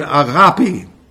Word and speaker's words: agape: Even the word love itself, the Greek word agape. By the way, that's the correct pronunciation.